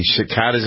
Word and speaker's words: cicadas: We also had a story, the cicadas. I never have pronounced that right. It's a hard C and a long A. Cicadas.